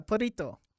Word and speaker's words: Perrito: Perrito. – Perrito, yeah. – Yeah, which is. – You got to learn how to roll your R's, by the way.